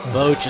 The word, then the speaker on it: Boach: Did I say that right? That's how you said it last time, right? – That's how I said it last time, yes. – I like it. Boach.